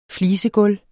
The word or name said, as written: flisegulv